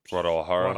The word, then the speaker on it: Guadalajara: Guadalajara. – Guadalajara. – Yeah, Spanish, the J's. – Soft J.